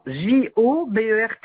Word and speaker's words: Jobert: Jobert. – Jobert. – Non, non, non, Jobert, comme ça se prononce. – Oui, Job comme Bernard, ERT.